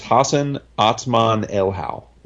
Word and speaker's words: Hassan Otsmane-Elhaou: But I'm pretty sure it's it's Hassan Otsmane-Elhaou. That's how you say it.